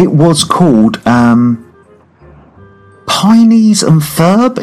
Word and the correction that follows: Phineas and Ferb: Is that how you pronounce it? – Phineas and Ferb. – Sorry, absolutely. Phineas and Ferb.